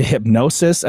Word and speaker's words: Hipgnosis: So Bieber sold his catalog to Hipgnosis, I think it's pronounced. There's a random G in there that I can't really sound out, but the music rights investment company announced this week.